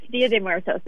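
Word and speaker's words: Día de Muertos: How do you say it? Dia de Muertos? – Yes, Dia de Muertos, the Day of the Dead.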